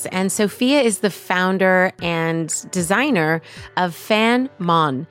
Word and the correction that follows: Fam Mon: It's Fam Mon, but it's a little heavier on the tongue. So I think you pronounce the M pretty heavily, which is nice.